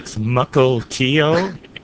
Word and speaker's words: Mukilteo: That's Mukilteo. That's an Indian name. It's spelled M-U-K-I-L-T-E-O, Mukilteo.